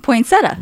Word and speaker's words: poinsettia: They like throw nine. Yeah, there's like nine syllables in it. Like, it's poinsettia. – Yeah, it's a poinsettia.